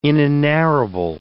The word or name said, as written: inenarrable